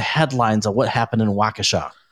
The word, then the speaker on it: Waukesha: Is that how you pronounce it, in Wisconsin, Waukesha? – Waukesha or something like that. – Waukesha, that's it, yeah.